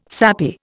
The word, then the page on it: sappy: Indian